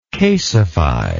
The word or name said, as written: casefy